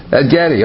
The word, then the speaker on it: Engedi: On this side, Syria, and behold, they be in Hazazon Tamar, which is Engedi. Okay. – Engedi. – Oh, okay. She knows how to pronounce these Hebrew words better.